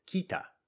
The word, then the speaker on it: kita: Notice in romaji, we would write that as kita, but we don't pronounce it as kita. We pronounce it as kita. Again, in this case, we make the first vowel mostly silent, as kita, which is the past tense of come, in other words, came.